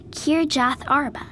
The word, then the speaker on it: Kirjath Arba: Kirjath Arba. K-I-R-J-A-T-H-A-R-B-A, spelled Sarah.